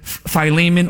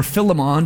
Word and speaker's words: Philemon: In fact, another book of the Bible in the New Testament, Philemon or Philemon, depending on how you want to pronounce that one, is written to a man in this area who had a slave.